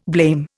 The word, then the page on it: blame: Indian